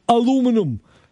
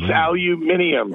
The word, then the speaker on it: aluminium: on how to say the word what we call aluminum. – Yes, aluminium.